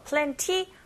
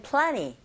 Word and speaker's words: plenty: 은 사운드, 티 사운드 맞물려 있는 경우 티 사운드 약하게 들려서 plenty 하셔도 되는데 귀에 들릴 때는 plenty 이렇게 들릴 수 있죠.